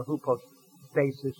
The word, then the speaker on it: hypostasis: And the essence is the Greek word, hypostasios, or hypostasis as we would pronounce the English, a mode of being.